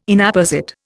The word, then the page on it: Inapposite: Indian